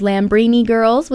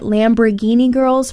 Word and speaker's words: Lambrini Girls: Before them was Lambrini Girls with the song Help Me, I'm Gay. And while putting this episode together, I realized I've been pronouncing it Lamborghini Girls for the longest time, so that was humbling.